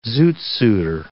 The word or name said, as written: zoot-suiter